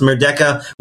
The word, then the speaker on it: Merdeka: I think that's how it's pronounced, Merdeka, which was being held in Malaysia, in Kuala Lumpur.